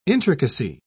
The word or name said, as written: intricacy